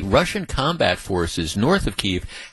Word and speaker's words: Kyiv: And by the way, I always pronounced it Kiev, but never again. What's been explained to me is Kiev is the way Russians pronounce the name of that city. Kyiv is the way Ukrainians pronounce it. So from now on, as far as I'm concerned, it's Kyiv.